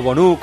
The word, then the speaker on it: Bono: Tremendo, ha ganado el Girona 0-1 con un gol de Perapón, pero es que Bono, o Bonu, como le llama alguno, se dice así el nombre, ha sido el gran protagonista del partido.